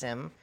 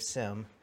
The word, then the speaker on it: Sim: And just personally, I grew up, my last name is pronounced Sim. When I introduce myself, I say, "Hi, I'm Dave Sim."